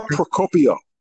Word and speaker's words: Procopio: Her opponent, Laura, how do you say her last name? Procopio? – Lara Procopio.